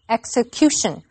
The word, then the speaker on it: execution: 这篇文章第一个重点我们来看 execution 这个名词。注意它的重音是在 cu 这个音节念成 execution，它是从 execute 执行这个动词衍生出来的。